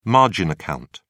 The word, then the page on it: margin account: British English